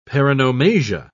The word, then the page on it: paronomasia: British English